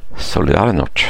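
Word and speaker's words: Solidarność: Den klassiska fackförbundet, hur uttalar man det här? Solidarinors. – Solidarinors. – Stannors.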